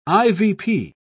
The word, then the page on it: ivp: British English